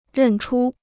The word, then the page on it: 认出: rèn chū